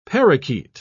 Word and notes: parrakeet: British English

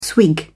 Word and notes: swig: British English

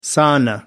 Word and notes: sauna: American English

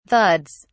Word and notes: thuds: British English

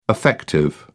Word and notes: affective: English; British English

